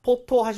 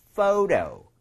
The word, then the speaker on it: photo: 그래서 포토 하시면 안 되고요. photo 이렇게 가셔야 됩니다.